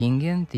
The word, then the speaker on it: recipijent: recipijent i korespondent imaju kratko uzlazni akcenat na pretposlednjem slogu, jer prema pravilima naše akcentuacije poslednji slog ne može biti akcentovan. N